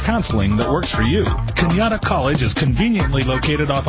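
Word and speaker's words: Cañada: Remember, sounds like Kenyatta, but spelled like Canada.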